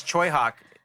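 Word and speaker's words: Tsui Hark: Yeah, it's Choi Hawk in Cantonese. Hawk.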